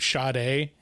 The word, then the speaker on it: Sade: S-A-D-E. And there was a very prominent R&B artist named Sade. – Okay. – It was pronounced Sade, but it was spelled S-A-D-E.